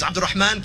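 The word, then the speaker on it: Abdulrahman: Mike, listen, man, if you can't say the name, it's Abdurrahman.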